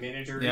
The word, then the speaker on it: menagerie: Menagerie? Yeah, menagerie. Yeah. – Menagerie is like menage a trois.